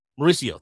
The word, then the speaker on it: Mauricio: Mercurio. Is it Mercurio? Did I say that right correctly? What Mauricio? Mauricio, thank you. Yes, I knew I was saying it wrong.